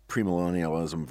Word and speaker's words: premillennialism: And not to get back into our premillennialism versus postmillennialism stuff, but... – But you're pronouncing them both right.